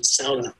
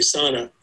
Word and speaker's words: sauna: Of course, the Finnish, the correct pronunciation, sauna. We say sauna.